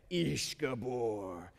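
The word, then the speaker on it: Ish Gibor: That's how you say it, Ish Gabor.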